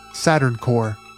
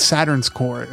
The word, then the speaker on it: Saturn's Core: Saturn's Core, Saturn Core. Is it? I'm always like Saturn Saturn Core. No, it's Saturn's Core. It's with an S.